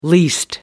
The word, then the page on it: least: American English